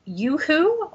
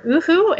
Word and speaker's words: UHU: So one is, I think it's pronounced Uhu or Uhu, it's spelled U-H-U.